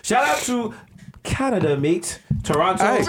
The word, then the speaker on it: Toronto: Toronto. – Don't pronounce that last T. – Don't pronounce that T, dog.